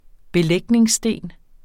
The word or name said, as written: vinterfoder